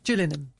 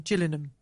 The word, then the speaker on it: Gillingham: I say it like the train drivers. Gillingham. We are now approaching Gillingham.